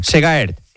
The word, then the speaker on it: Segaert: Y en ciclismo, el belga Alex Segaert, ¿lo he dicho bien? – Segaert. – Sí.